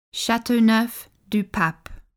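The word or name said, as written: Châteauneuf-du-Pape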